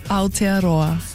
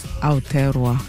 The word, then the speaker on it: Aotearoa: Aotearoa. – Aotearoa.